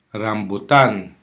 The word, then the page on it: rambutan: Hiligaynon